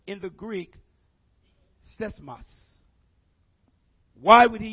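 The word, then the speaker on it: seismos (σεισμός): In the Greek, it is it's pronounced seismos. It's when we think about seismos, it's used. We get our English word seismic from this word.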